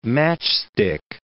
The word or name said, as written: matchstick